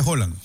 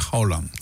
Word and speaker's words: Haaland: Haaland se pronuncia, fase. – No, Haaland. – Haaland. – Yo decía Haaland. – Haaland. – Es Haaland, claro. Doble A se escribe, ¿no? Doble A y se pronuncia Haaland.